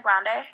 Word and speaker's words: Grande: How do you pronounce her name? Grande or Grande? – Ariana Grande. – Ariana Grande. Okay.